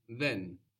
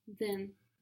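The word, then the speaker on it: Then (δεν): The word for don't or not in Greek is then. – Then. – This is spelled with delta, the Greek letter that looks like a D, or in capitals, it looks like a triangle. Then. – Then. – So this is like the TH sound in the English word then, like I came, then I saw him.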